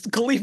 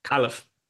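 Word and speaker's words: Kalif: It's Khalif Raymond is receiver four. Like. – I'm pronouncing Khalif wrong. I love this. This is good. – Khalif.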